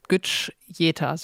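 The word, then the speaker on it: Güçyeter: Wow, unser Deutschland-Märchen von Dinscher Gücseta. Gücseta. So, jetzt habe ich es nochmal richtig ausgesprochen.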